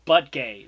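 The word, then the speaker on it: Buttigieg: It's butt gay. Like that's really what it is. Like spelling it's BUTTGEIG, butt gay.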